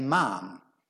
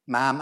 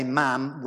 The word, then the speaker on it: ma'am: And then the second and subsequent times you speak to the Queen, you say, Ma'am. Ma'am, and they always say ma'am rhymes with ham.